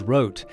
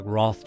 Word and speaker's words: Roth: Volker kept the German pronunciation of their name, which is Roet. Despite what looks like Roth to Americans, Axel chose to go by Roth.